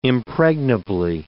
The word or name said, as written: impregnably